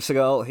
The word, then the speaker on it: Segal: The Stasi, in this case, chose professor and biophysicist Jacob Segal. Siegel, I don't want to pronounce that like the martial art. Well, um, but Jacob Segal,